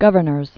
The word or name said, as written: Governors Island